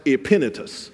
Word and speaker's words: Epaphras: So that is pronounced Epaphras, who is the first convert to Christ from Asia.